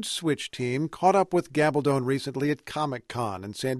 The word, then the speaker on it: Gabaldon: Gabaldon, she says her Spanish surname rhymes with bad to the bone, has a Ph.D.